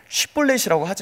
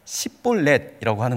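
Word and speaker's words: 쉽볼렛: 쉿볼렛을 해보라고 합니다. 그런데 이게 동편과 서편의 억양의 차이가 있기 때문에 쉿볼렛이라고 하지 못하고 십볼렛이라고 하는 거예요. 그렇게 발음하는 사람들을 길르앗 사람들이 다 죽였습니다.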